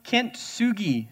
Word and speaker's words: Kintsugi: Kintsugi. So I'm probably totally butchering this Japanese name, but it's Kintsugi. Kintsugi.